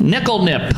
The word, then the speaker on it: Nikl Nip: Nickel nip. Is that right? – Spell it. – N-I-K-L-N-I-P. – Yeah.